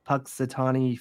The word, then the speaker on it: Punxsutawney: The groundhog though, it's Punxsutawney Phil.